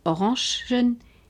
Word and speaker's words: Orangen: Orangen est le pluriel de orange qui est prononcé et écrit à la française.